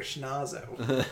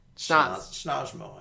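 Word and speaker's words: Schnozmo: No, I'm the Fairly Odd Parent's twin brother, Schnazzo. The evil Schnazzo. – I still can't get the name right. Schnazzo? – Schnazmo, I imagine, because he's Cosmo's brother.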